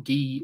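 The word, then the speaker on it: Gui: So rhymes with Guy, so it's Guy Santos. Okay, thanks, Rand Simon. Guy Santos. I have to remember that it's Guy.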